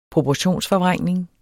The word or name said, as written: proportionsforvrængning